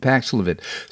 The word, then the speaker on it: Paxlovid: Paxlovid.